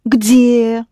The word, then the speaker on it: Где: Да, где. Of course. Here, е comes after д, and д, где, pronounced soft.